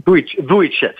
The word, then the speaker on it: Vujicic: How do you pronounce his last name? – Vujicic. Yes.